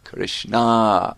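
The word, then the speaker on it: Kṛṣṇā: That means the Supreme Lord Krishna met with Draupadi, who's also known as Krishna, with a long A.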